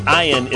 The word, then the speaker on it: ayin: The ayin is pronounced like the I in pie.